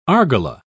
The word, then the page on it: argala: British English